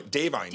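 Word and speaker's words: Da'Vine: I think it's pronounced Divine. Davine. – Davine.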